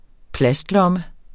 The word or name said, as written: plastlomme